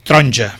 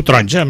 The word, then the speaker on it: taronja: Moltes vegades aquesta vocal tendeix a desaparèixer. Es pronuncia amb poca força davant de la següent, que és la tònica forta, i desapareix. Per exemple, safreig. – Sí. – Taronja. La gent diu taronja, m'he menjat una taronja avui.